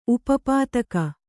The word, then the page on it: ಉಪಪಾದುಕ: upa pāduka